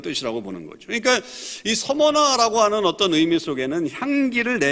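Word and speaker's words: Smyrna: 이것은 이유가 있는데 이 소모나라고 하는 이름과 아주 밀접한 연관이 있습니다. 소모나라고 하는 이름의 뜻이 무슨 의미냐면 이게 시미르나 이렇게 이제 발음도 되는데 이거는 향료를 얘기합니다.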